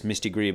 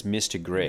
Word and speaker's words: Mystigri: Okay, so I would have pronounced this Mystigri, but maybe it's Mystigrix. Maybe. I just don't feel like German or French usually, I don't feel like you pronounce the X, but then otherwise it's identical to Mystigri, the archaic term for cat, which is spelled without an X.